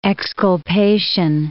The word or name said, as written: exculpation